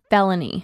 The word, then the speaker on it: Filoni: It's pronounced felony.